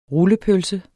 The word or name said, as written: rullepølse